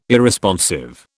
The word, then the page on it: Irresponsive: British English